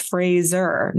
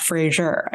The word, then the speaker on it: Fraser: And same with even Brendan Frazier, which I found out his name is Fraser, not Frazier. I was like, what? But come on. – he was like, it's like a razor.